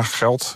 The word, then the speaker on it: Gelt: Want die nieuwe munt, die heet de Geld. Spreek je dat goed uit? – Ja, Geld. Ja. G-E-L-T. – G-E-L-T, ja.